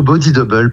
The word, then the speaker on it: Body Double: Alors le projet Body Double, plutôt Body Double parce que c'est plutôt un mot anglais normalement.